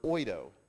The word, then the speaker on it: oida: The second word for the word knowledge in Greek is the word oida, which is spelled O-I-D-A.